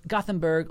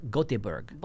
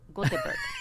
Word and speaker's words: Göteborg: How do you say that? Gothenburg? – Gothenburg or Göteborg. – Göteborg.